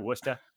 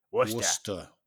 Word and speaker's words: Worcester: It's spelled Worcester. Like Worcestershire sauce on this steak. – Yes. – Yeah. – But they say Worcestershire sauce, so it's the same pronunciation.